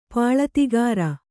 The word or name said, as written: ಪಾಳತಿಗಾರ